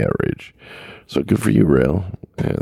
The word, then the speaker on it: Roehl: Well, it looks like for the fourth year in a row, Rail Transport, which is R-O-E-H-L, they pronounce it rail, won the safest, the prestigious, or what is it?